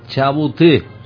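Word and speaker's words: Chabouté: Madre, me ha apuntado por aquí: Purgatorio de Chabuté. No sé si mi pronunciación es correcta o no. – Sí, muy bien.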